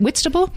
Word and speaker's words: Whitstable: Am I saying that right, Katherine? Is it Whitstable? – You're saying it exactly right, yeah.